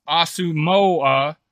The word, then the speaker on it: Asamoah: Asamoah, pronounced Asamoah, family came to the United States from Ghana in 2000 and eventually settled in Columbus, Ohio.